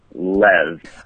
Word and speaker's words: Liu: So it's actually pronounced Lev.